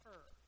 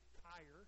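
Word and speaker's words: Er: His firstborn is not Ur. It's E-R, but it's not Ur. It's actually Ire. If you can try to pronounce it the right way. It's Ire.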